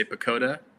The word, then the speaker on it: PECOTA: I just say Pacoda because that's what it's spelled like, P-E-C-O-T-A.